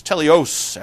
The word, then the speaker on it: teleios: Teleos, actually, is the pronunciation. T-E-L-E-I-O-S.